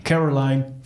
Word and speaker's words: Caroline: Carola, moet je nemen? Caroline van der Plas. – Radio Caroline. – Ja, volgens mij moet je dat zo uitspreken.